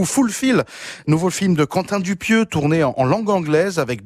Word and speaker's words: Quentin Dupieux: In France, we say Quentin Dupieux. – Oh, Quentin Dupieux.